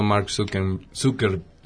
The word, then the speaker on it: Mark Zuckerberg: Y se vio muy involucrado Mark Zuckerberg.